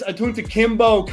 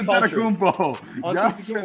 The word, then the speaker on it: Antetokounmpo: You butchered his last name. – I can't say it, bro. I don't. I'm Giannis Antetokounmpo.